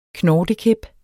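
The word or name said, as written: knortekæp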